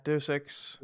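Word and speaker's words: Deus Ex: øh, Legacy of Kane og øh, Dirk, Deus X. Er det sådan, det udtales? Det, ja, Deus X.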